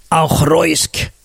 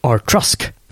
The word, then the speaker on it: Auchroisk: Auchroisk.